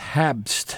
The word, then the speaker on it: Habst: We started with the DJ and producer, Sean Porter, who goes by the name of Bloody Snowman, with Habst. I don't know if I'm pronouncing that right. H-A-B-S-T. Like Pabst Blue Ribbon. Mm-hmm. Habst. Part of an album called, I'm going to, K-L-Z-X-R-M-X.